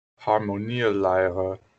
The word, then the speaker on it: Harmonielehre: You say it as Harmonielehre.